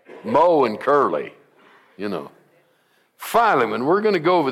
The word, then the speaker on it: Philemon: Now, I call it Philemon. Some call it Philemon. Put the long E in there, don't they, Sherry? And so Sherry says, Pastor, you say that wrong. And it is Philemon, but because she corrected me, it's Philemon. But it definitely is not Philemon.